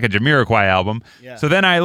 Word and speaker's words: Jamiroquai: I'm afraid I'm saying it wrong. But it's Jamiroquai.